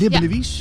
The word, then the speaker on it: Libbenewies: Dat doe ik met Barbara Lenobre en leerkracht Annelies van de Friese school Libbenewies. Zeg ik dat goed eigenlijk? Libbenewies? – Ja, helemaal keurig.